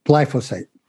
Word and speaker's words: glyphosate: Glyphosate, am I saying it right? – Glyphosate.